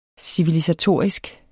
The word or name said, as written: civilisatorisk